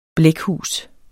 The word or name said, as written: blækhus